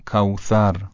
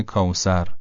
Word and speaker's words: کوثر: مثلا کوثر به جای کوسر، نوک زبان یه مقدار که از بین دندون‌ها بیاد بیرون تلفظ درسته.